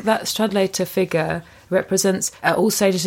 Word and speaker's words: Stradlater: There's also a disgust at, is it Stradlesser? I can never know how to say it. – Stradlater. – Oh, yeah. – That's how it's spelled.